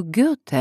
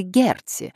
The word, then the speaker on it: Гёте: Улицу Гёте они называют улицей Герти.